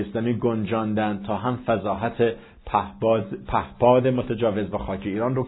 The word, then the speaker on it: پهپاد: پهباد پیشرفت این کشور ارزیابی کرد. البته تلفظ صحیح پهپاد هست و نه پهباد که به عادت بعضا اشتباه میگوییم.